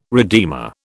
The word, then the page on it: redeemer: British English